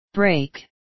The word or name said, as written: break